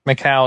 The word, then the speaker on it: Macau: mailing address, and my old housemates will send it here to me in I can't pronounce that word. Macau? – Macau is how I would say it. – Okay. – So yeah, that's how you say it.